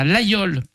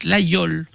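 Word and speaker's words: Laguiole: Nous sommes allés aussi passer une semaine à L'Aïole, pas la gueule. Hein. On prononce L'Aïole hein, pour les autres.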